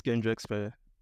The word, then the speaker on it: Kayndrexsphere: It's Kayndrexsphere.